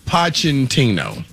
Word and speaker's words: Pochettino: Pochettino. – Pochettino? – Pochettino.